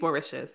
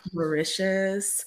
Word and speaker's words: Mauritius: Did you say Mauritius? – I think it's Mauritius, yeah. – Mauritius.